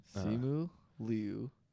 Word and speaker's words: Simu Liu: Yeah, he tweeted pronunciation for his name and Simu Liu.